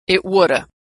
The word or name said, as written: it woulda